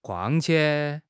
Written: 况且

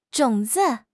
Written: 种子

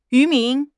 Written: 渔民

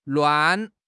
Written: luan4

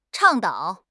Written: 倡导

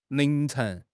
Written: 凌晨

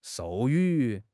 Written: 授予

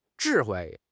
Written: zhi4 hui4

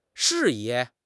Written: 适宜

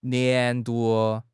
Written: 年度

